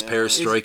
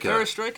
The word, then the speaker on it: Perestroika: Is that how you pronounce it? – That is Perestroika. – Perestroika is how you pronounce it.